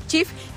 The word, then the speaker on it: Cheaf: Básicamente es una aplicación que la puedes descargar tanto para iOS o como Play Store, donde básicamente al descargar CHEF, que les voy a decir que se escribe CHEAF, porque no se pronuncia tal cual, entonces es muy importante que la descarguen así.